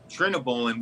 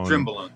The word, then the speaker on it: trenbolone: I'm like, well, I started the conversation off with that, so everyone knew, because I called it trenobolone, which is not the way you pronounce it. He's like, well, obviously this guy doesn't know what he's doing. It's pronounced however the hell it's pronounced. We always just call it Tren. – Well, it's Trenbolone.